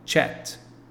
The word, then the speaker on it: chat: Outro exemplo, sobre conversa ou bate-papo, a pronúncia correta é chat.